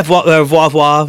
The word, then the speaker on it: Voir Voir: Yeah, Voir Voir.